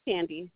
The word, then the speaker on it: Sandy: My first name is Sandy. – You say Andy? – Sandy, S-A-N-D-Y. – Yes, oh, Sandy. And your last name? – Gauna. – Can you spell it, please? – G-A-O-N-A.